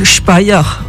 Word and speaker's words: Speyer: Est-ce que tu peux déjà nous dire où ça se trouve, euh, Spire Oui, alors Spire, euh, qu'on prononce Speyer en Allemagne, et c'est une ville portuaire qui est située sur le Rhin et c'est un arrondissement au sud du Land Rhénanie-Palatinat.